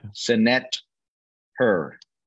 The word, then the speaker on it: Senetter: What is the proper pronunciation of that cigar? – Senetter. Which means incense in ancient Egyptian, which it really translates into of the gods, but it translates as incense.